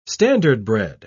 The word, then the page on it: standardbred: British English